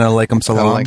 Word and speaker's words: alaikum salam: And then alaikum salam? – Alaikum salam.